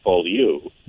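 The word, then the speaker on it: Beaulieu: Is it Ballou? – That'll do. If we're in the Midwest, I pronounce it Ballou. – Ballou. Okay. – That's the Ponzi French version of it.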